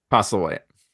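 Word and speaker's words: Postlethwaite: Postlewaite.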